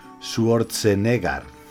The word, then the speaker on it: Schwarzenegger: Bueno, el nombre, el nombre se pronuncia Arnold Schwarzenegger.